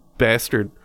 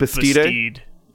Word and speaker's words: Bastide: Anyway, enter stage right, the Herald Bulletin of Anderson, Indiana, with an article titled Creature and Plant Nine Pits by Ken de la Bastard. – I'm pretty sure that's not how that's pronounced. It's spelt Bastide. – Or Bastide.